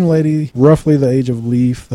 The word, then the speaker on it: Lief: I would say Leif. – I was saying Leif, too. – Okay. It's spelled L-I-E-F, but I always pronounced it Leif.